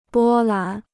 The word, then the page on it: 波澜: bō lán